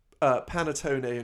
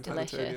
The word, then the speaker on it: panettone: Oh God, coffee and panettone is amazing. – Yeah. – Sorry, Italians, for pronouncing that wrong. Probably. – No, I think that's right.